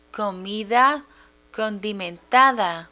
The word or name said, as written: comida condimentada